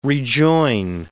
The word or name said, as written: rejoin